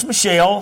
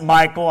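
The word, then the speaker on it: Michal: But then Saul saw his younger daughter, her name was Michelle. Some people pronounce it Michael. I've always said Michelle.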